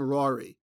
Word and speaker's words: Ruairidh: So the boat guy, played by Finley Curry, his name is Raw. I'm going to call him Rory, but it's spelled Rory. It's the spelling is R-U-A-I-R-I-D-H. Those Scots, man.